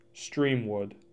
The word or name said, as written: Streamwood